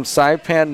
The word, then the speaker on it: Saipan: She's a junior from Saipan, Northern Marianas Islands.